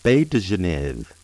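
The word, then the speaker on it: baies de genièvre: Juniper berries in French are pronounced baie de Genève and spelled baie, B-A-I-E-S, de, D-E, Genève, G-E-N-I-E-V-R-E. In French, they can also be pronounced baie de Genèvrier, which is baie, B-A-I-E, de, D-E, Genèvrier, G-E-N-E-V-R-I-E-R.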